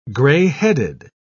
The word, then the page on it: gray-headed: British English